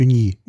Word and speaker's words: Ugni: und die Sorte Uni Blanc U, also UGNI geschrieben. Ich will es jetzt hier nicht komplett falsch. Wie, wie spricht man Wenn das? – UG? UGNI. Ja. Äh, Uni, Uni. Uni Blanc aus der berühmten Cognac-Region.